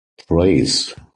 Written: preys

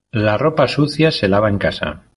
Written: ropa

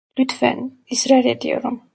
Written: ediyorum

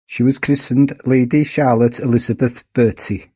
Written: christened

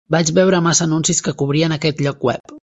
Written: cobrien

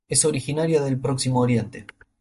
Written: Oriente

